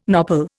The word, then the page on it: nopal: Indian